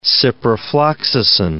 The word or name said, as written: ciprofloxacin